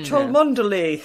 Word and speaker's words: Cholmondeley: Like the Cholmondeley Warners as well, which is Cholmondeley.